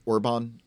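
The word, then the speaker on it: Orbán: Orban in Hungary.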